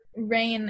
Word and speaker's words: Rain: So I spell it R-A-I-N because it's like people know how to pronounce it.